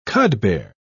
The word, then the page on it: cudbear: British English